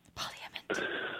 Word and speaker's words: Parliament: Parliament. – So that's how it's spelled and pronounced, Catherine. – I know, but I never hear anyone say the yeah bit.